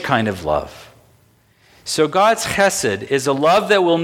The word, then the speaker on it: chesed: would pronounce it in the Hebrew way, which is chesed. Chesed. It's spelled H-E-S-E-D, and it's pronounced with the ch at the front.